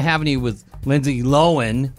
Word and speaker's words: Lohan: Lohan. – You know, now that we know how to say her name.